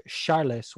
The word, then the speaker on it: Charles: Actually, you know, I learned recently from listening to Fernandez podcast, it's actually pronounced Charles, which makes sense because it's, you know, Portuguese, but I just never thought, like, oh, just say Charles.